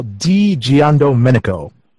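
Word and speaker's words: Di Giandomenico: Digia Domenico. – Now I'm gonna scroll through because I read this. I enjoyed it as well. Digian Domenico.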